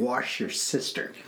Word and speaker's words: Worcestershire: I'm pronounced it and wrote it out. Wash your sister.